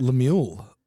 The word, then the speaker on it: Lemuel: How do you say that? – Lemuel. Lemuel. Lemuel.